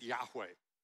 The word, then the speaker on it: Yahweh: Y-H-W-H. How do you pronounce that? Well, I said Yahweh. You know why? Because vowels were added to facilitate pronunciation.